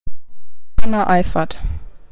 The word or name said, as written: Hannah Eifert